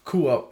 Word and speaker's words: Qua: Kua? – Kua. It derives from Semitic language. – Spell it. – Kua. Spelling is Q-U-A Pops. Kua, okay.